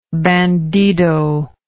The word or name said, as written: bandido